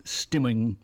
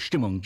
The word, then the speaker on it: Stimmung: But yeah, it's a German word, so stimmung is stimmung. – How it's pronounced.